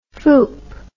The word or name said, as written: fooper